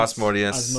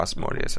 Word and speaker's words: Asmodeus: Is that how it's pronounced? Yeah, Asmodeus. That's how I say it. I mean, there's probably multiple ways you could say it, but yeah, Asmodeus, I think, is the best way to say it.